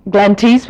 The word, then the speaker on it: Glenties: I myself am from Ardara, which is spelled A-R-D-A-R-A, and most Americans would say Ardara, and Glenties would be pronounced Glenties because it's G-L-E-N-T-I-E-S, and it's Donegal, not Donegal, but that's okay.